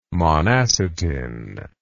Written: monacetin

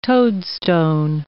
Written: toadstone

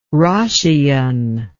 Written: roscian

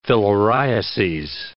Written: filariases

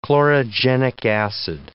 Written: chlorogenic acid

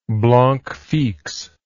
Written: blanc fixe

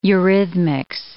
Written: eurhythmics

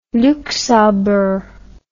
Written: Luxembourg

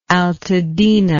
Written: altadena

